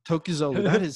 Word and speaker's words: Tokgozoglu: Tokgozoglu.